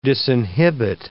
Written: disinhibit

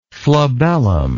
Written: flabellum

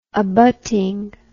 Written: abutting

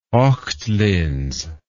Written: oughtlins

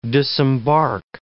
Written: disembark

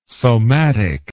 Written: somatic

